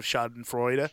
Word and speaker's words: schadenfreude: And I feel not bad at all about enjoying that bit of schadenfreude. Even if some truly despicable people also got jobs yesterday, you know what I mean? – Mm-hmm. So, nice pronunciation on schadenfreude.